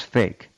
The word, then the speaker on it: PHEIC: P-H-E-I-C. – They make those rules too. – Yeah, P-H-E-I-C, pronounced fake.